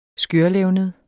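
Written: skørlevned

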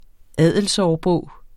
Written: adelsårbog

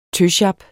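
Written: tøsjap

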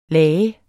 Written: lage

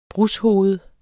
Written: brushoved

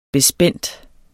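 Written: bespændt

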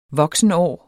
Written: voksenår

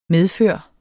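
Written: MEDFØR

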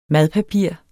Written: madpapir

